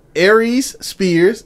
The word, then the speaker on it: Aries Spears: Aries Spears. Did I say it right? – Yeah, let's do it. Aries Spears and Tiffany Haddish.